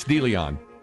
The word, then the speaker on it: DeLeon: The person with the 281 said, how's DeLeon pronounced? Yeah, it's DeLeon.